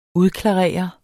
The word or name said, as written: udklarere